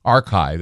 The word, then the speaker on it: arXiv: The group has published a paper describing their findings on the preprint server of ARXIV, archive, that's how they spell it, ARXIV, archive, I guess.